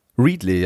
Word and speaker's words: Readly: Readly, ja.